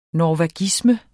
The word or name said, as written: norvagisme